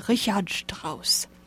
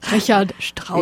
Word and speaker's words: Richard Strauss: So today we're talking about the opera Arabella by Richard Strauss. Excuse my German pronunciation. – Yeah, that's very good. You do it again. – Richard Strauss.